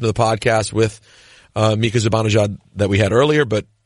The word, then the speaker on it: Zibanejad: So that's how you say his name. Zibanejad.